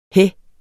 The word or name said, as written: hæ